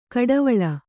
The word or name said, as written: ಕುಡಿವಳ್ಳೆ